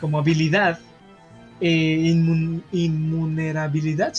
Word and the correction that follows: invulnerabilidad: ¿sí se dice así? Invulnerabilidad.